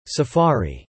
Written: Safari

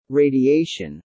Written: radiation

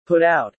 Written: put out

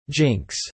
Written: jinx

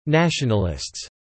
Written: nationalists

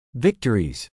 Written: victories

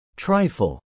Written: trifle

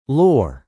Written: lore

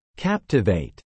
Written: Captivate

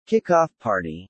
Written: kickoff party

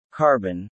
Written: carbon

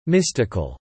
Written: mystical

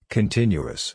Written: continuous

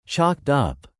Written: chalked up

